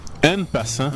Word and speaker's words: en passant: É, eu falo francês, não é en com A-N, é en com E-N. – En passant? – En passant. Você pronuncia como se fosse A, mas se escreve com E.